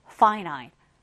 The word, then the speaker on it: finite: finite, finite. 여러분들 이거 finite라고 읽지 않도록 유의하셨으면 좋겠고요.